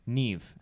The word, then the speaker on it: Niamh: So this is N-I-A-M-H. These words look like they're like from an elven language. – Yeah, Neve. It's like someone's just throwing letters together randomly. – Neve is what I'm saying.